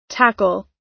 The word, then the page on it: tackle: American English